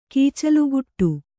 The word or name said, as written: ಕೀಚಲುಗುಟ್ಟು